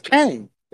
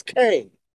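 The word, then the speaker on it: Qeng: Quinn Tower, Q, it's Q-E-N-G Tower. – It's Kang. That's what it says. It's Kang. – Oh, is that how you pronounce that? Because that's fucking weird. That's how I'm pronouncing it. – Okay, I'm going to pronounce it. You know, Kang makes sense.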